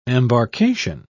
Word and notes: embarcation: British English

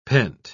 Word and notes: pent-: British English

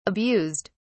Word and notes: abused: British English

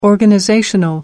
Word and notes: organizational: British English

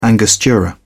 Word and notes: angostura: British English

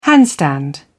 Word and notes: handstand: British English

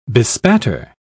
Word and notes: bespatter: British English

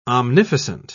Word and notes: omnificent: British English

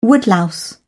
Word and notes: woodlouse: British English

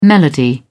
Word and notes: melody: British English

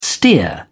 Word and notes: steer: British English

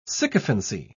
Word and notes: sycophancy: British English

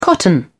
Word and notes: cotton: British English

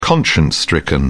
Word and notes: conscience stricken: British English

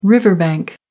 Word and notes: riverbank: American English

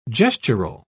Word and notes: gestural: British English

